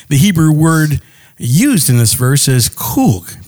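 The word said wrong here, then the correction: chug (חוג): pronounced chug, sorry.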